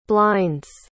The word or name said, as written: blinds